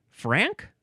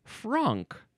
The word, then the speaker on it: franc: Now, it says that I'm supposed to pronounce it Frank? It's F-R-A-N-C. I would want to pronounce it Franck.